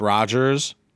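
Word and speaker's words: Rojhaz: You get Raj Haas, pronounced Rogers, Virginia's blonde-haired, blue-eyed Native American bodyguard.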